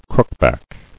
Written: Crookback

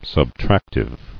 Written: Subtractive

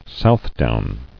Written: Southdown

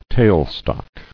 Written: Tailstock

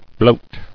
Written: Bloating

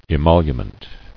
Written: Emolument

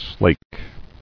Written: Slaking

